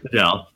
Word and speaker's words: Bedell: Bedell, yeah. From Greater Than Games. – It rhymes with and Bedell from Dragon Ball Z.